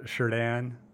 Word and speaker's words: Chardin: Oh, Chardin. It's got the CH in front, but it's actually a SH sound to it.